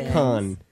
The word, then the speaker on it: Cannes: What is it, Cannes, or Cannes? – It's Cannes, like... – Because... – Kick the can.